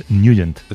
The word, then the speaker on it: Nugent: Hombre, siempre lo hemos llamado en el barrio Ted Nugent o Ted Nugent. – Claro, ¿sabes? Yo era de Ted Nugent. – Pero la pronunciación correcta es Nugent.